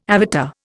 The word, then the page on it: avatar: Indian